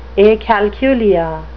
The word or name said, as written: acalculia